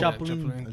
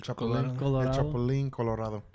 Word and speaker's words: Chapulín: So I liked El Chapo del Chavo del Ocho, and then El Capulín, I think that's what it's called. – Chapulín. – El Chapulín Colorado.